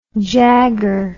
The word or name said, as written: Jagger